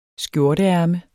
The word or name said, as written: skjorteærme